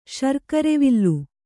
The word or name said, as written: ಶರ್ಕರೆವಿಲ್ಲು